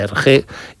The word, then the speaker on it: Hergé: y utilizó la R de Remy, que allí se pronuncia R, uh-huh, R de Hergé, y el nombre de George G. RG.